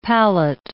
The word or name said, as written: palate